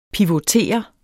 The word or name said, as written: pivotere